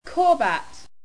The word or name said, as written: Korbat